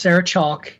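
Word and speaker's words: Sarah Chalke: Oh, uh, speaking of favorite television shows, her name is pronounced Sarah Chalk. The E is silent.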